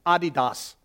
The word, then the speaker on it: Adidas: You must be American saying Adidas. It's Adidas. So if you really want to impress your friends, you can say I'm wearing Adidas, They'll think you're a little weird, but that's actually the right way to say it.